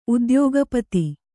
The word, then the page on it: ಉದ್ಯೋಗಪತಿ: kannada